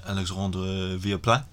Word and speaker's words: Alexandre Villaplan: Alexandre Villaplan.